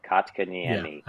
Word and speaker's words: Kotkaniemi: And then same thing with the player they offer sheeted from Montreal, Jesper Kotkaniemi. I probably mispronounced that. Kotkaniemi. Yes, That sounds good. – That I think is correct.